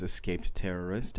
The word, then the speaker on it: EscapedTerrorist: My name is Escaped Terrorist, and I'm seeking verification on Reddit's Gone Wild Audio.